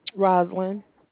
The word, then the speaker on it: Rosalyn: Roslyn. – Can you spell that for me? – R-O-S-A-L-Y-N.